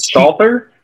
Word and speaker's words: Stalter: Meg Salter is amazing. Is it Stalter? How do you spell it? – It's S-T-A-L-A-L. I'm saying all. S-T-A-L-T-E-R.